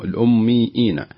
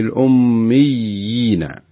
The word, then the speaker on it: الأميين: انتبهي انتبهي، انت كان فيه شيء من الهمزة، تقول الأميين. الصحيح أن تقولي في الأميين، يعني يا أين كلها لينة، يعني لا تحتاج أنك تنطقي بشيء في من الهمزة،